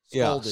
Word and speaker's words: scalding: Scalding. Rhymes with spalding. – Not I'm going to scold you. But scalding. – Gonna scald you with hot water. The singer Esperanza Spalding? – Correct, yeah. – Rhymes with that.